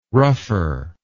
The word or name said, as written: rougher